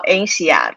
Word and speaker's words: INSEAD: Yeah, so first of all, I want to correct one of your pronunciations. – Oh, my MBA is called INSEAD. It's a French school.